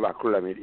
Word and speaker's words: acrylamide: acrylamide. I'm not sure if I'm saying that right. – Acrylamide. – Yes, thank you so much.